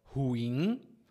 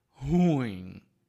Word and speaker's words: ruim: A palavra ruim é tem essa pronúncia, mas muitos brasileiros vão dizer ruim. Eu também digo. então tem muita gente que vai pronunciar errado, de acordo com as regras.